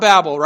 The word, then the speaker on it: Babel: And we grew up probably, if you were a kid in church, you'd hear the Tower of Babel, right? And maybe Babel would be a better pronunciation, but we'll go with Babel.